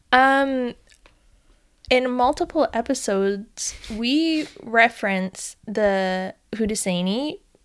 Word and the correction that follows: Haudenosaunee: peoples. – Oh, yes. – And that's not how you pronounce it. – Oh. – Or at least that's not how the people in my certificate online course are pronouncing it. – Okay, tell us. – Okay, well, now I forget, so give me a moment. – Haudenosaunee. – Oh. – Haudenosaunee. – Yes.